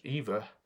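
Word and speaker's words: Aoife: Spelt the Irish way but pronounced Eva, Eofy Grace Moore is a journalist and broadcaster, currently with the BBC and resident in Southern Ireland.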